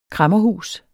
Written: kræmmerhus

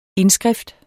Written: indskrift

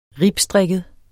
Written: ribstrikket

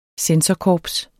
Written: censorkorps

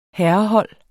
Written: herrehold

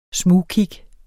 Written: smugkig

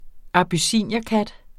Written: abyssinierkat

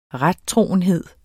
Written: rettroenhed

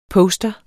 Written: postere

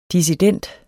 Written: dissident

